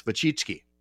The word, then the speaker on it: Wojcicki: And what people don't know, and I'll put it up on screen for people watching on Facebook, her last name is spelled W-O-J-C-I-C-K-I. And that's Wachitsky, I think. At least a couple times I've heard her pronounce it, you know.